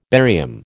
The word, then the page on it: barium: American English